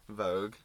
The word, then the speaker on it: Vogue: Vogue.